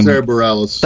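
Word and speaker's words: Sara Bareilles: Sarah Bareilles.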